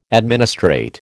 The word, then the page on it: administrate: American English